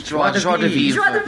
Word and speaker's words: joie de vivre: Damn you, Gwyneth Paltrow, and your infectious joie de vivre. Anybody speak French? – Joie de vivre.